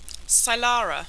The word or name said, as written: cylara